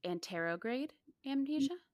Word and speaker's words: anterograde amnesia: On to Jody with anterograde amnesia? – Mm-hmm. Yep. – Okay. Did I say it right? – You did.